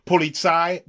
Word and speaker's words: politie: The Dutch police officers, or politie, but it's spelled like polities, they were very, very polite.